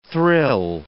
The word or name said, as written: thrill